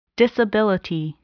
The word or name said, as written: disability